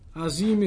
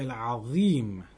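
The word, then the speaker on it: العظيم: Azim is pronounced incorrectly. It's not a za, it's a dha. Subhana Rabbi al-Azim, not Azim.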